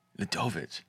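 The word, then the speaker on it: Ľudovít: Wait, how do you say Klein's first name? – Ladovic. – Okay. – Yeah, it's kind of like how it's spelled.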